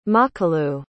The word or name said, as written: makalu